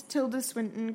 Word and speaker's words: Tilda Swinton: And before all this, Tilda Swinton comes on...